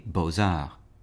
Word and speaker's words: beaux-arts: For example, with two words such as beaux-arts, the first word is spelled B-E-A-U-X, and the second word is A-R-T-S, but we hear the Z sound from the X connecting to the A, beaux-arts.